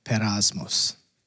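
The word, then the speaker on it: peirasmos: Here is this word, perasmos. There, that's thank you. I appreciate that because I clicked the button on the Bible study and that's how you say it.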